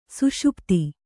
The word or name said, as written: ಸುಷುಪ್ತಿ